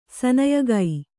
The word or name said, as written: ಸನಯಗೈ